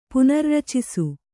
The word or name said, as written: ಪುನರ್ರಚಿಸು